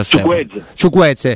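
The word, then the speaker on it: Chukwueze: Ciucquese, come si pronuncia? – Ciuqueze. Sam, Samu Ciuquese.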